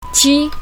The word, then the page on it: ji1: mandarin